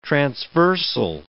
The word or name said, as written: transversal